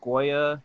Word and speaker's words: Goya: But like, I'll say, for instance, like in the Goya, that's the way you pronounce that company, right?